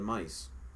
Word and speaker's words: mais: It's funny because in Italian they call it mice. – So which British? – But not spelled like the mouse. It's spelled M-A-I-S. – Oh, we spell it M-A-I-Z-E.